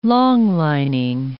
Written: long-lining

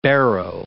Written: barrow

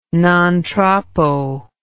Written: non troppo

